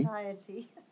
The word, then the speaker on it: satiety: Satiety. – Satiety. – Another tricky one. Satiety, you know, as in, you know, satiated, satisfied. Yeah, but it's pronounced satiety.